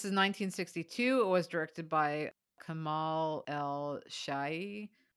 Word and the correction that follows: Sheikh: Sheikh, I think. – Sheikh. Sorry, world.